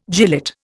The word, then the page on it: gilet: Indian